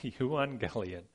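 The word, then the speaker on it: euangelion (εὐαγγέλιον): Okay, euangelion.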